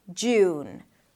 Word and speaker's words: June: Jew, and then close it with an N. June.